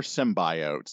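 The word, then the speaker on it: symbiote: no, no more symbiotes. That's how it's pronounced.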